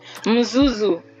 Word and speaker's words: Mzuzu: So is it Mzuzu E-Hub? Did I pronounce it right? Yeah, so that led you to founding Mzuzu. My guy.